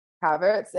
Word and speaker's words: Havertz: Havertz.